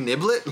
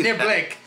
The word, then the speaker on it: niblick: What sport used the term mashy niblick? it's a sport you know. – Badminton. – I'll give you one more try. – Cricket? – No, golf. – Rugby. Mashy niblet? Let me see. – Niblick.